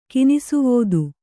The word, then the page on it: ಕಿನಿಸುವೋದು: kannada